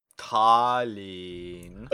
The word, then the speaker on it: Tallinn: So, on Tuesday, September 27th, 1994, MS Estonia set out for Stockholm from the ferry terminal in Tallinn. – Tallinn. – Thank you. – With the doubled and tripled vowel sounds.